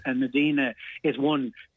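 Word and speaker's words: Mdina: Is it pronounced Medina Town? – Yeah, so Medina is a hill town.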